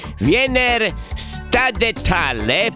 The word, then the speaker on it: Wiener Stadthalle: Anzi, la voglio pronunciare bene. Wiener Stadthalle.